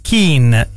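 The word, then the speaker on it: Keane: Pronuncia cognome anglosassone di Ken è Keen, Ilunga con Tukin o Roy Keen, Ciocco GPT.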